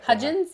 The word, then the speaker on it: Hudgens: Hudgens? Am I saying it properly? Yes. Okay.